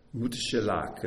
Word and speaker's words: Methuselah: And Methuselah comes to the word mut. Mut shelach is really how it's closer how it should be pronounced.